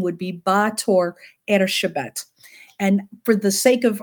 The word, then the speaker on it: Erzsébet: And Elizabeth in Hungarian is Erzsébet.